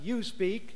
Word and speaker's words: USPEQ: USPEQ, pronounced YouSpeak,